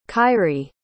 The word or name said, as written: khayri